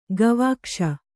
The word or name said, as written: ಗವಾಕ್ಷಿ